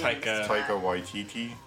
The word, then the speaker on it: Taika Waititi: Don't put me Taika Waititi.